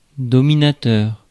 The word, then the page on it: lord: /lɔɹd/; /lɔːd/